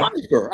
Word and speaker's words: Pyfer: Leave you know Piper. like huh? pie, like pie in the sky. – Piper. I thought I said Piper.